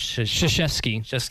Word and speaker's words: Krzyzewski: Coach Krzyzewski.